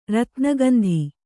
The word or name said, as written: ರತ್ನಗಂಧಿ